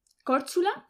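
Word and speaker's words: Korčula: ¿Córchula? – ¿Córchula? – Que se escribe Córcula.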